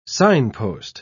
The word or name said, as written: signpost